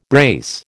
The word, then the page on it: brace: American English